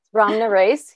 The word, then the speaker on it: Ramnarace: Ramnarace, yeah.